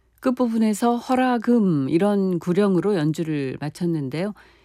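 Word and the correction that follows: 훤화금: 이 부분은 정확한 발음으로 하면 헌화금이죠.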